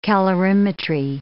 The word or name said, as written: calorimetry